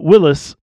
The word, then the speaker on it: Willys: And I wouldn't say Willys, like, or Willys, like, right. – like, you know, what you talking about, Willys? but Willys, and that's how it's pronounced.